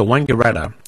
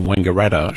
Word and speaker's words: Wangaratta: Historically, there's been disagreement about whether the first syllable in Wangaratta should be pronounced Wong or Wang. The Wong pronunciation was never used by a majority of the population and never received any wide use outside the district.